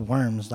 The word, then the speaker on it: Worms: And finally, he got his great say at the Diet at Worms, basically a trial in the town. It was called Worms. It was spelled Worms, like with a W, but it's pronounced Worms.